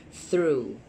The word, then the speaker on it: through: It's so difficult because GH here is silent. Because the vowel. Through.